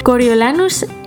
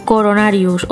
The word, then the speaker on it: Coriolanus: Coriolanus Snow, o sea, decirme que no soy la única que ha estado toda la primera mitad del libro diciendo Coronarius o no sé, cualquier otra cosa.